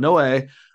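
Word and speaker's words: Noé: We're going to start with our guy, Noe, that's how you pronounce it. You know, we went back and forth on how to pronounce it. It's the Spanish version of Noah. I looked this up, but Noe.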